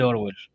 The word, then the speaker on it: Orwell: Un ejemplo rápido que se me viene a la mente, 1984, el libro de George Orwell. ¿Cómo se pronuncia? – Sí, Orwell.